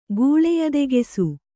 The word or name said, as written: ಗೂಳೆಯದೆಗೆಸು